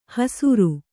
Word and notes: ಹೆಸ್ಸರೆ: kannada

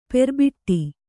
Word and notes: ಪೆರ್ಬೊಟ್ಟು: perboṭṭu

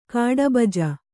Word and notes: ಕಾಡುಬಜೆ: kāḍu baje

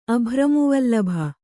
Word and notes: ಅಭ್ರಮೂವಲ್ಲಭ: kannada

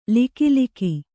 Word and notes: ಲಕಲಕ: kannada

